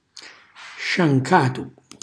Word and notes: sciancatu: Calabrese

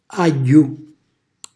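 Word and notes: agghju: Calabrian

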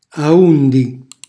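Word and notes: aundi: Calabrian

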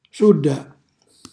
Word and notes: sulla: Calabrese

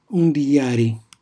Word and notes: undijari: Calabrese

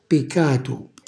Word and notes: picatu: calabrese

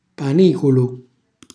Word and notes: paniculu: calabrese

